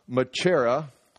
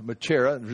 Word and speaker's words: machaira: And the word sword, right here, is this word, and it's machaira, M-A-C-H-A-I-R, machaira, just like that, ma-chair-a. That's how you say it.